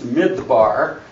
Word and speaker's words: Midbar: Midbar, as I have here in these English characters.